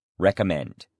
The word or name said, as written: recommend